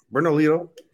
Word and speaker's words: Bernalillo: How would you say that one? Bernalillo, right? – Yeah, Bernalillo.